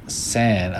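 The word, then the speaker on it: 3ND: And Sand, I'm gonna look this one up. Sand, it's spelled the number three, then ND. San is three in Japan, so sand.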